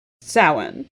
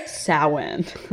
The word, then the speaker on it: Samhain: And the angels were like, "Samhain." and the angels and Sam and Dean are like Sam Hain, and Castiel's like Sowen.